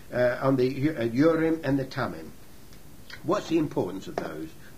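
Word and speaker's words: Thummim: Also, put the Urim and the Tummim, it's a silent H there, the Urim and the Tummim in the breastpiece, so they may be over Aaron's heart.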